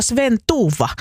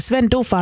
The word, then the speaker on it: Sven Duva: Missä teoksessa on hahmo Sven Duva, että se olisi väärin, että pitäisi olla Sven Tuva, niin ei tarvii olla. Kyllä se Sven Duva on se alkuperäinen hahmo ja sitten kun suomalaisille tuommoinen Duva on liian vaikea ääntää, niin se sitten vielä suomennettiin Sven Tuuvaksi.